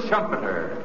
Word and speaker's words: Schumpeter: Joseph Schumpeter, whose name rhymes with trumpeter.